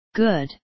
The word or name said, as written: good